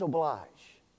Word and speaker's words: oblige: Noblesse oblige. N-O-B-L-E-S-S-E. Oblige or oblige, however you want to pronounce it. O-B-L-I-G-E.